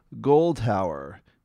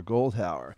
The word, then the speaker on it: Goldhour: Again, that was cantor Avraham David Fuchs, and the choir was conducted by Shlomo Goldhauer. I think that's how you pronounce it, G-O-L-D-H-O-U-R, Goldhauer. Let's hear one more short selection of Cantor Fuchs with Ki Lekach Tov.